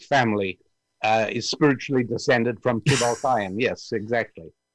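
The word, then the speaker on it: Tubal-cain: Tubal Cain, which is one of the descendants of Cain, if I'm saying that correctly. – Very good, yes.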